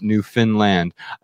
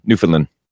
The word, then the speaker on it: Newfoundland: I can't pronounce Newfoundland, or is it Newfinland? Yeah, yeah. – But I think it's Newfoundland. – Newfoundland. – Newfoundland. That's it.